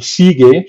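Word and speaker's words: Siege: Es un poco complicado y complejo el tema, pero hay utilidades como Sigue, se escribe S-I-E-G-E, -E, g e verdad em, esto te crea varias requests.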